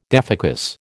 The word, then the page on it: defocus: American English